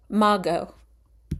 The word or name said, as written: Mago